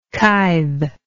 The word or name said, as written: kythe